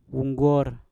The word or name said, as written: wongor